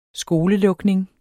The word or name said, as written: skolelukning